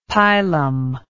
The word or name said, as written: pilum